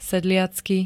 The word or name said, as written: sedliacky